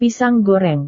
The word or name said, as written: pisang goreng